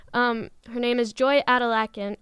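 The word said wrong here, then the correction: Oladokun: or no, Aladikin, that's how you pronounce it.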